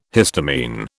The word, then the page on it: histamine: British English